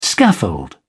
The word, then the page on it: scaffold: British English